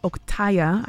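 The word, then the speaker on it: Oktehya: It's spelled O-K-T-E-H-Y-A. I'm still trying to confirm that I am pronouncing it correctly. Oktaya, I believe it is.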